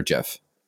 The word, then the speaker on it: GIF: But until then, there was this article that came out that the creator of it actually said that it was pronounced like the peanut butter JIF. – Oh, interesting. I mean, so that's not like phonetically spelled, but for some reason, I always want to say GIF.